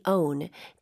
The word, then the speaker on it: own: 오, 쌤, 여기 own, O-W-N 발음 한 번만 다시 부탁드릴게요. – Own. Own. – 네, 이거 입모양을 벌리면 원처럼 되기 때문에 살짝 주의하셔야 돼요. 오, 운에 오히려 더 가깝겠죠.